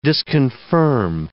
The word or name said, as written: disconfirm